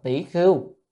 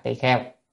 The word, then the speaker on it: tỳ kheo: À, để chúng ta hiểu tỳ kheo ở miền Bắc đọc đọc là tỷ khưu, tỳ kheo gì đó, thì nhiều danh hiệu nhưng mà chúng ta đọc từ miền Nam ha, tỳ kheo.